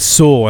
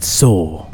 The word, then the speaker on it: Saw: I asked him, and he said it's Saw, and I said, so it's like Thor, but Saw.